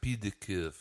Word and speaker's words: Pied de Cuvée: We pronounce it Pied de Cuvée here.